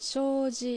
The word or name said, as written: Shouji